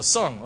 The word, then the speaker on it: Tsang: How do you say his last name? Someone help me out. Tsang. Oh, T silent. Okay. Oh, Sung. Okay. So Mr. Donald, right, the bow tie guy, right?